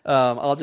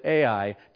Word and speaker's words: Ai: Maybe you pronounced it Ai, depending on who you are. I like Howard's like, I call it Ai, and I'm like, well, I call it I, and I'm preaching.